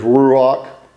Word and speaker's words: ruach: I can't say it like a Hebrew scholar can, but the Hebrew word is ruach. You're supposed to say it where you sound like you're trying to spit.